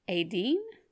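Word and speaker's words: Étaín: Pagan Ireland would celebrate and honor the goddess Aideen. It's spelled Etain.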